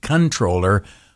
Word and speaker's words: comptroller: And I know you're supposed to pronounce it controller, but to me, comptroller sounds cooler.